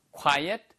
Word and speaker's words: quiet: 이거를 quiet는 quiet이에요. quiet. 이거는 조용한이란 뜻이고요. quiet이라고 쓰면은요, 그건 quite, 꽤란 뜻입니다. 그러니까 발음 구분하세요. 얘는 quiet, 그다음에 꽤라고 할 때는 quite, quite good 이렇게 얘기할 때 쓰는 표현이죠.